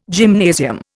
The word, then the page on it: gymnasium: Indian